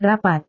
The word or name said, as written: rapat